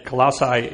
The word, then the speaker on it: Colossae: I put it in one of those computer things, and that's the way they said it, Colossae. Does anybody want to challenge that? Colossae is the way that I believe it's pronounced.